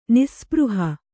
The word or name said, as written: ನಿಸ್ಸ್ಪೃಹ